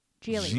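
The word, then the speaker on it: Gigli: Geely. Rhymes with really.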